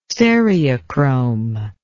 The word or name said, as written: stereochrome